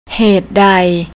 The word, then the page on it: hatedai: thai